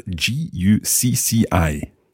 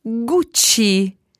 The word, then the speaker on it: Gucci: First of all, there was the one that spelled G U C C I. – Gucci. – Gucci.